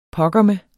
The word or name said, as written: pokkerme